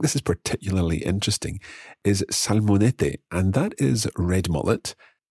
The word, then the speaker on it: salmonete: But in the recording, you'll hear the fishmonger pronouncing it as salmonete, and that's where the th sound, the th sound, if you like, is replacing S, as it happens in some parts of Spain.